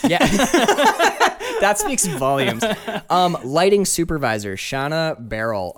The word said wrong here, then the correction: Barry: Okay, Barry. Sorry, Shayna Barry. – Shayna Barry. Okay, silent L.